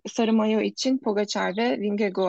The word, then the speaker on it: Vingegaard: E, Vingego diye okunuyor ama hep okurken Vingegaard diye okuyorum ben.